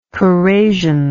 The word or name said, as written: corrasion